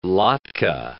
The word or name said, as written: latke